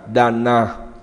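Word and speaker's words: dāna: Thưa với đại chúng, bố thí phạn ngữ được đọc là Dana.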